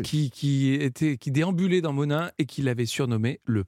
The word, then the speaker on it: Monein: À propos de Monin, pour ceux qui n'habitent pas dans la région, il faut savoir que c'est écrit M-O-N-E-I-N, et ça se prononce Monin.